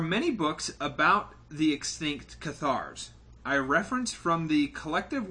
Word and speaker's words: Cathars: But for this week's Nook of Darkness, we have the Cathars. Am I pronouncing that right? C-A-T-H-A-R-S? – Sounds right.